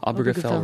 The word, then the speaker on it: Obergefell: That's the Obergefell versus Hodges. Is that how you pronounce it? – Obergefell versus Hodges case.